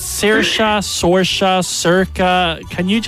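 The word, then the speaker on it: Saoirse: Well, I prefer it like Erica with an S. So it's pronounced Erica with an S, but it's like Sirica. It's Irish for Sarah. – Sirica. – Yeah, Sirica. – So pronounce it like Erica with an S, but it's Sirica. Like, it's Irish for Sarah, but it means like bright.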